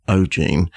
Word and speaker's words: O'G3NE: So that was the Netherlands and a group called Ogene, spelt O-G-3-N-E, but it's pronounced Ogene.